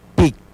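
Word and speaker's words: pik: Se pronuncia pic.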